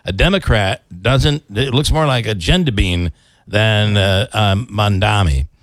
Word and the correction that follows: Mamdani: Mamdani. It's Mon, isn't it? Mondami? – M-A-M. – M-A-M. Mamdani. – Mamdani.